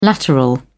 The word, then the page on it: lateral: British English